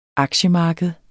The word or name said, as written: aktiemarked